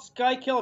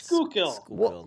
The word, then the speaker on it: Schuylkill: I thought it was the Schuylkill River. What's the Schuylkill River? Did I make that up? – It's not called Schuylkill. It's Schuylkill.